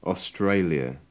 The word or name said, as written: australia